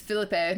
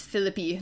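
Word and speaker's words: Phillippe: I always thought Ryan Philippi's last name was Philippe until I heard him in an interview, and he's like, actually, it's pronounced Philippi.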